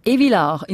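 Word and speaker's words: Evilard: Zum Beispiel jetzt gerade von der Sonja Buringer aus, jetzt muss ich schauen, Evilar in der Nähe von Biel, habe ich es richtig gesagt, Frau Buringer? – Ja, genau.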